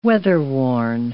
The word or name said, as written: weatherworn